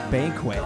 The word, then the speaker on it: BNQT: It's spelled B-N-Q-T, and it's pronounced Banquet.